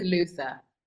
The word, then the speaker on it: Luther: You are one of the stars of Luther, the Fallen Son, or should I say Lufa? This is the most important question I'm going to ask you today. How are you pronouncing it? Are you doing this on set? Is it Lufa? Is it Luther? – Odette says Luther.